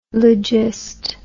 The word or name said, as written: logist